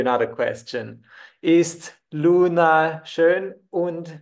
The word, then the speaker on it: und: Yeah, make sure you pronounce it as und and not as and, like it would be in English. It's slightly different.